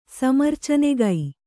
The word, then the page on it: ಸಮರ್ಚನೆಗೈ: kannada